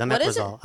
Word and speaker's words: Omeprazole: Omeprazole, that's what it is. Omeprazole.